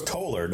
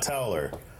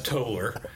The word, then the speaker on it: Toller: it's Toller, not Towler. – I know it looks like towel. – Yeah, it's the couch thing, you know. That's how you know if you actually live here. – It's pronounced Toller.